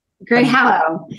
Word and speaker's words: Greyhallow: Did I pronounce that right? Greyhallow, Grey Hollow? Greyhallow. Grey Hollow, yeah. sorry.